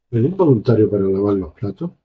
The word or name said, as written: platos